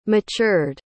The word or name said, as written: matured